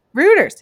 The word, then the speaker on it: Reuters: Reuters.